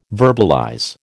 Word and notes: verbalize: American English

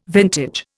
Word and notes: vintage: Indian

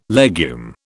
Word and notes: legume: British English

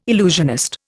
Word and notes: illusionist: Indian

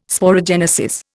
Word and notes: sporogenesis: Indian

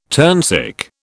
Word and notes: turnsick: British English

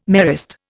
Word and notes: Marist: Indian

